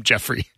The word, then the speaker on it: Geoffrey: It's named Jeffrey. But spelled with a G because it's fancy.